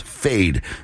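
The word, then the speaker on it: Feid: Fed. Noche without you. New Music Showdown Challenger right here on C89.5 Morning. Actually, it's pronounced Fade. It's spelled F-I-E-D, but Fade is the artist.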